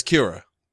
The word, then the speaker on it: Kira: The reason they call him Kira is because that's the Japanese pronunciation of killer. It's Kira.